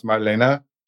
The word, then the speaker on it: Marlene: It's Marlene, but it's actually, it's German, so it's pronounced Marlene.